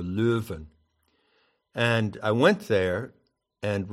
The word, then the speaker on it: Leuven: And it's associated with the town of Leuven, to give it the Dutch pronunciation, and strongly associated with the figure of Erasmus.